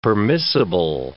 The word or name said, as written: permissible